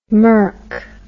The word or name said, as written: Merck